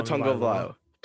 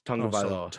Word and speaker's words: Tagovailoa: I learned how to pronounce his name this past weekend. It's Tua Tagovailoa. It's like tongue. Like Tagovailoa? – No, like the body part, tongue. Tagovailoa.